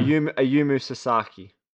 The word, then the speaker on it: Ayumu Sasaki: Ayumu Sasaki.